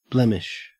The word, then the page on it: blemish: Australian English